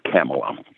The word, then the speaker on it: Kamala: just say Pamela with a K. It's Kamala.